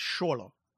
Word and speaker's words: Xolo: if you want, I think, so the thing about that X is it's kind of a sh sound. So I think the way you pronounce his name is Xolo.